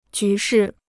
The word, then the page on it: 局势: jú shì